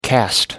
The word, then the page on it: cast on: American English